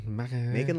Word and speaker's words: Macon: Macon like bacon.